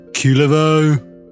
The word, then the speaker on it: Kullervo: Cujo. – Cujo. – It's pronounced Kulevo.